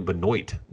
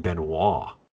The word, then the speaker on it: Benoit: Oh, it is Ryan Benoit, not Ryan Benoit. That completely wrong.